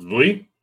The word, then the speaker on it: Viy: It's called Vli. It's spelled V-I-Y, if we're looking at English. The thing is that it uses a letter in the Russian alphabet that doesn't exist in English.